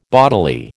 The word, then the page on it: bodily: American English